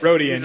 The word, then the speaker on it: Rodian: All right, so are we going with Greedo specifically, yes, or are we going just Rodians in general? – Nope, Greedo. – Rodian? Rodian? I don't know how to say it. – Rodian, yeah.